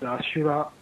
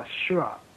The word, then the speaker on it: Asura: But the Asura part, this word is Sanskrit, and in Japanese it's Asura or Ashura.